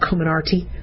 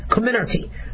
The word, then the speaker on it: Comirnaty: It's going to be hard to say that, Comirnaty. Comirnaty. I think the accent is on the second syllable.